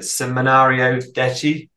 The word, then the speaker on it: Seminario Detti: This album, like if you take the the, the final track, which uh, apologies I can't pronounce it, Seminario Detti. Yeah, spot on, perfect.